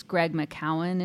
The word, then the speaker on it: Greg McKeown: And, he says the author, I think it's Greg McKeown is how you say it.